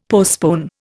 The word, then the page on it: postpone: Indian